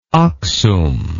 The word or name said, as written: Aksum